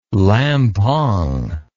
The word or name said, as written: lampang